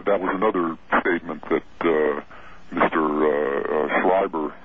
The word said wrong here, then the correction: Strieber: uh, uh, Strieber, Strieber. made uh, on your program that.